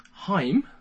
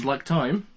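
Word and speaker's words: Haim: We have Heim. – Heim. – Like time.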